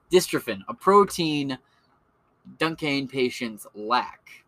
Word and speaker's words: Duchenne: Duchenne.